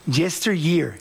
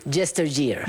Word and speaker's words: Yesteryear: Caro Claire Burke, se pronuncia Yesteryear. – Yesteryear. – Yesteryear, así se llama, pero así está en español el libro.